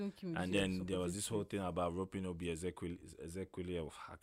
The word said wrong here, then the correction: Ezekwesili: Obi Ezekwesili. – Ezekwesili.